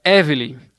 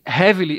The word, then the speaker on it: Hevelly: É por causa do H, ele se escreve H-E-V-E-L-L-Y, aí muitas pessoas pensam que é Evelyn, mas não é Evelyn, é Evelyn, o H é mudo.